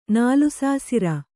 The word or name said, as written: ನಾಲುಸಾಸಿರ